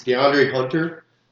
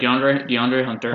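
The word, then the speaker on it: De'Andre Hunter: That's DeAndre Hunter. Is that how it's pronounced? – Yeah. – Their rookie draft pick? – Yeah. – Yeah, DeAndre Hunter.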